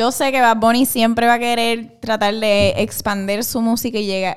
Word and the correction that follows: expandir: Expander, lo dije bien. – Expandir. Expandir. Puñales.